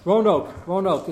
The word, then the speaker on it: Roanoke: It was Roanoke. Am I pronouncing it something? – Roanoke.